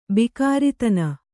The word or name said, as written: ಭೋಗರತ್ನ